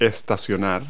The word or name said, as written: park